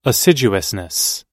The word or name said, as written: assiduousness